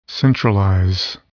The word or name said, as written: centralize